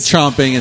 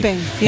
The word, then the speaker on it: champing: I think it's champing. – I like chomping. – I do, too. I've always said chomping, and someone corrected me recently. – It's champing, yeah.